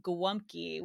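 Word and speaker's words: gołąbki: And yes, I pronounce these things like a Chicagoan with a Polish accent because this is how it was pronounced. – That's who you are. – There was pierogi and also gwumki, which I don't know if you've had that.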